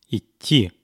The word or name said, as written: idti